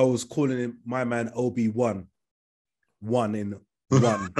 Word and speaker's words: Obi-Wan: It's Obi-Wan. Yeah. W-A-N. – Yeah. – But it's pronounced one. They say one though, Obi-Wan.